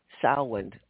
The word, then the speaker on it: Samhain: This was named Halloween, or started with the ancient Celts, who called it Samhain. I always have trouble with that name, but that's how it's pronounced. It looks like it should be something different.